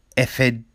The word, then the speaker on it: Etihad: And uh, so at a football game uh, between Burnley and Manchester City, right, happened at the Etihad Stadium. I mean, that's how you pronounce it.